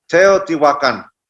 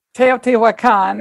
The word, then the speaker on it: Teotihuacan: And you talk about Toltec traditions and I'm going to butcher it, Teotihuacan. – Teotihuacan. – Teotihuacan.